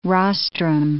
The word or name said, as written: rostrum